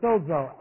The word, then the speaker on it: sozo: It's sozo. S long O, Z long O.